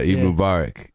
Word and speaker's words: Eid Mubarak: I saw that, because it was my first time ever hearing about this, but Mubaraka, am I saying it right? Like, it's a holiday, it was in the... – Eid. Okay, I was at Eid Mubarak.